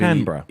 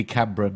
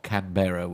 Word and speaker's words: Canberra: Canberra. – Yeah, absolutely. Yeah, this is it. Should be Canberra, not Canberra, which I keep doing.